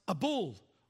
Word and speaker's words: Abel: Now, his name is pronounced Abul. So, Cain and Abul.